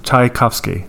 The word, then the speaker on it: Tchaikovsky: Say it one syllable at a time. – Chai. Cough. – Cough. – Ski. – Tchaikovsky.